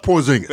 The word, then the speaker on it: Porzingis: It's Kristaps Porzingis.